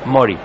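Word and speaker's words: Mory: ¿O es Mori o es Mari? Para mí que es Mori. – Mori, bueno.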